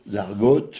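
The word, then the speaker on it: Argoat: De l'argoate, j'espère que c'est comme ça qu'on prononce, l'argoate, l'argoate, oui, à l'Armor.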